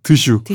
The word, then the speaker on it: 드슈: 그런 시대에 나왔던 게 아까 했던 길벗, 타슈. 아, 타슈가 아니죠. 드슈. – 드슈. – 이런 술들이에요. – 그렇게 발음 안 될 것 같아요.